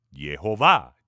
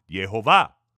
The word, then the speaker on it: Jehová: los cuales nos dejan saber que la pronunciación de su nombre es Jehová, Jehová.